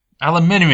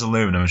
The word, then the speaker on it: aluminium: Is it pronounced aluminium or aluminum? – Aluminium, you fool. Anyone says aluminum should be kicked out.